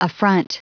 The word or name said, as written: affront